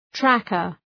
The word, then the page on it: tracker: ‘trækər